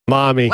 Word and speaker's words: Mami: This year, one of the opening acts was a rapper named Face Tat Mammy. – Mommy. – What? – Mommy, but it's spelled M-A-M-I. Mammy.